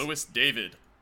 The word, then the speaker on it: Luis David: Is that how you say it, though? Is it like Louis David? – Louis David. Okay. – Okay, so I'll just... – Louis David. – Yeah, that's how I would say it.